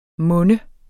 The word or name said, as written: monne